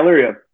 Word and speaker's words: Elyria: Elyria, Ohio. Am I saying that right? – Yeah, Elyria.